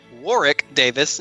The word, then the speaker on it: Warwick: And our narrator that evening, I've been saying his name wrong all these years because the announcer clearly called him Warwick Davis, not Warwick, which is what I've been saying,